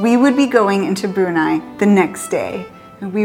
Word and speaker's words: Brunei: And I don't know if it said Brunei or Brunei. I've looked it up. It seems to be Brunei in English, but the local people here do say Brunei, and that's what it's spelled like.